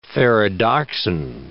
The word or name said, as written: ferredoxin